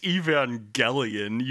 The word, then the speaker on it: Evangelion: It's pronounced Evangelion, you fuck.